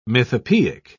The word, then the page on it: mythopoeic: British English